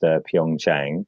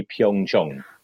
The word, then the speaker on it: Pyeongchang: He sent me a link to something saying that Pyeongchang is not pronounced Pyeongchang. It's the latter half of it rhymes with gong, so it's really Pyeongchang.